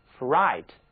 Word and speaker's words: fright: Fright 这个词表示的就是惊恐，吃惊，它是一个名词，字母组合 GH 不发音，而字母 I 则需要大家把嘴巴张大，读作 I, fright, 非常饱满的双元音 I。